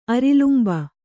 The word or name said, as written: ಅರಲಂಬು